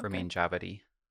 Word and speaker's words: Ramin Djawadi: Ramin Djawadi.